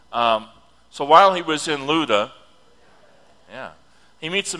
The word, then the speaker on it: Lydda: Now it says, it's spelled Lydda, and I've always had trouble with that word, but I finally am getting it down. It's Lydda. Say it with me.